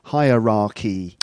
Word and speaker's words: hierarchy: The point is that it's pronounced like the comparative word higher. High, higher. The highest. Higher. Higher. Higher. So there's a hierarchy.